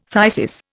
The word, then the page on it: phthisis: Indian